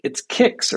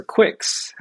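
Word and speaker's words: Qix: You can't say that about the next game on the list, however, Volfide, however you pronounce that, which is, it's Kicks or Quicks, however you want to pronounce that.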